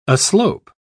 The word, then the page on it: aslope: British English